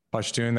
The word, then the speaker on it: Pashtun: Pashtun.